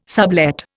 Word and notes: sublet: Indian

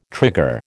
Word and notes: trigger: American English

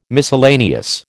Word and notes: miscellaneous: American English

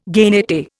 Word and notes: genette: Indian

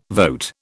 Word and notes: vote: British English

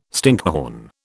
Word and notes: stinkhorn: British English

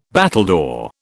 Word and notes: battledore: British English

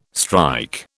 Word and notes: strike: British English

